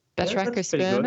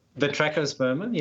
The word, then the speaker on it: Batrachospermum: It is Batrachospermum. Batrachospermum? – Yeah, that's pretty good. Yeah. – Batrachospermum, yeah.